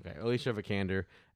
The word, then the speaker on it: Alicia Vikander: Is it Alicia Vikander? Is that how you say Alicia? – Yeah, Alicia Vikander. – Alicia Vikander. So I actually went ahead and stayed with Alicia Vikander here.